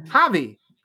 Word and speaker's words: Javi: And we're going to end it with Javi. So it's a soft J, but it's still a J. It still counts. Javi, who's Fantastic.